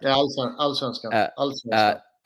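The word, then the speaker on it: Allsvenskan: In his opinion, and I might butcher this, Alisvenskan, is that correct? – Alisvenskan.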